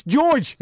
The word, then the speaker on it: Jorge: It's like when I was doing a TV show, right, with Jorge Masvidal. No, it's George, right? It's George Masvidal.